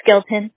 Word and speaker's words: Skelton: No, it's Skeleton. – Okay. How do you spell that? – S-K-E-L-T-O-N.